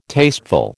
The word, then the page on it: tasteful: American English